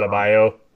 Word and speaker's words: Adebayo: Adebayo.